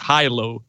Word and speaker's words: Hilo: The thing is, I'm not sure if it's a word for real, but in my head, it's supposed to be pronounced Hilo, because I just set up the word, merged high and low, so that's where it came from.